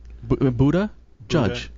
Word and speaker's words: Buttigieg: Buttigieg. – Yeah, it's not happening. – Buddha? Judge.